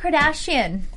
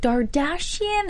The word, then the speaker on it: Kardashian: Robert Kardashian. Mm. We see him come into the media for the first time. People are like, Dardashian? They're pronouncing his name wrong.